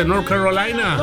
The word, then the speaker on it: North Carolina: Toda la gente que está por acá, repetimos el saludo, dice, para Rico, para Luis, Galia, Tilita, Avi, Carolina del Norte, North Carolina.